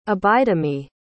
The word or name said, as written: abidemi